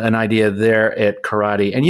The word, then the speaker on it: Corradi: Um, it's spelled C O R R A D I, Corradi. Uh, just just think of it with an Italian accent, Corradi.